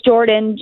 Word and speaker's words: Jordyn: it's Jordan, J-O-R-D-Y-N, and really emphasize the Y.